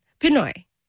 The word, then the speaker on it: Pinoy: My associate producer, who's also Filipino, says it's Pinoy. You're calling it Pino. – Pinoy. Yes, Filipino. – Say it again. – Jack's Filipinos. And then Pinoy is like our nomenclature.